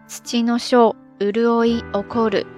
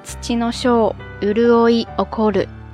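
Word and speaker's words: 土脉潤起: 首先，第一候土脉润起，在日语当中读作土の小潤い起こる。土の小潤い起こる。土の小潤い起こる。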